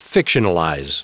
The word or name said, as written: fictionalize